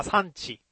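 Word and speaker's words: SAN値: まあ、産地のチェックなんかは、これはクトゥルフ神話 TRPG ですかね。クトゥルフ神話のシリーズ。まあ、産地、 SAN 値、 SAN 値って書いて産地って読んだりするんですけどね。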